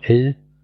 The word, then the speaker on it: Elle: Ist nämlich ein französischer Film, nur das E hinten nicht mit ausgesprochen, deswegen einfach nur Elle.